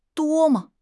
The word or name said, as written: duo1 me5